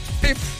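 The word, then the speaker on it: Pipe: Et bien sûr, musique Café Lewis, Cure Pip. J'espère que je le prononce comme faux, hein? P-I-P-E. C'est ça.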